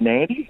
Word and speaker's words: Nadi: So you fly out of Melbourne to Nandi, which is really weird because it's spelt N-A-D-I, but it's pronounced Nandi.